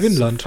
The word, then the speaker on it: Vinland: Spricht man dann wirklich Winlandsaga aus? – Äh, Winland. V. – Da kannst du ja auch einfach Finnland-Saga nennen. Nee, ist Winland.